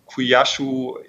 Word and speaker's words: Kuaishou: Und äh, ja, da ist die Kuyashu eben in einem sehr fruchtbaren Börsenumfeld äh, an den Markt gekommen. – Da ich es nicht besser aussprechen kann, ähm, denke ich, du hast es genau richtig ausgesprochen.